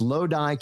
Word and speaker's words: Lodi: It's pronounced Lodi, California.